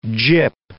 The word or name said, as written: gyp